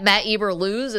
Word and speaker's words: Eberflus: Or at Matt Eberflus, is that his name in Chicago now? – Yes, technical name. – that's how you pronounce it.